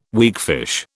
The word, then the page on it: weakfish: British English